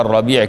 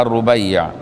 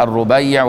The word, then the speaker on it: الربيع: وإن كان في بعض النسخ مكتوبة الربيع كده ومتشكلة بهذه الطريقة، هو الأولى الربيع، وهو الصواب، وجزم بالصواب في فتح الباري وغيره، وكتاب أسد الغابة في معرفة الصحابة جزموا بأن تنطق الربيع وهي ايه منسوب الى امه حارثة بن الربيع الانصاري، قتل يوم بدر وهو حارثة بن سراقة.